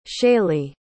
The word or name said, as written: shaley